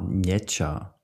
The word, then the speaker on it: Nmecha: Oder Niemcza. N-M-E-C-H-A. Niemcza, glaube ich, so wird es ausgesprochen.